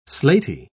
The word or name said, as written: slaty